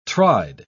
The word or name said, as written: tried